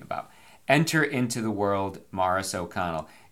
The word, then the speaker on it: Maurice: If you're wondering, it's M-A-U R-I-C-E, like Maurice. If you saw that name, you'd probably say it Maurice or Morris, like Morris the cat. I think Morris spelled M-O-R-R-I-S. Nope, it was spelled like Maurice, but the man always said it Morris, and how one says his or her name is the way it is said.